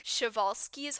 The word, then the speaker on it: Przewalski: It's spelled like PRZEWALSKI, but it's pronounced like Chevalsky's horse.